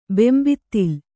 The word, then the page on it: ಬೆಂಬಿತ್ತಿಲ್: kannada